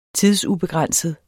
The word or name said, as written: tidsubegrænset